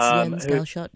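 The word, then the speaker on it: Jens Galschiøt: Probably pronounced it wrong. – No, it's Jens Galschiot.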